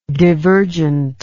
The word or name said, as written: divergently